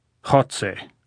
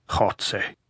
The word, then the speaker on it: Goltz: How do you say Goltz in Spanish, Comrade General? – Jotze, said Goltz, grinning, making the sound deep in his throat as though hawking with a bad cold.